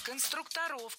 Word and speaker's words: конструкторов: Вот именно конструкторская команда, навскидку, это сколько было человек, отряд конструкторов? – Конструкторов, конструктора, это неверно. В слове «конструктор», опять же, ударение не меняется. Всегда сохраняется на «у» во втором слоге.